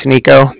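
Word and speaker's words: Niko: All right, yeah, like I said, my name's Nico. That's N-I-K-O. And then I'm calling from the Bank of Cardiff, and that's spelled C-A-R-D-I-F-F, as in Frank.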